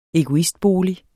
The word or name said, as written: egoistbolig